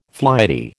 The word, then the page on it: flighty: American English